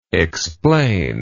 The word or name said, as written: explanator